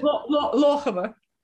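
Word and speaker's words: Lokhova: Lokova. – Lokova. – Yes, because so the K is supposed to be silent. It's the way that you translate the H.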